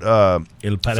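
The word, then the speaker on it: El Paraíso: I think I'm pronouncing that wrong, but El Paraiso. Factory.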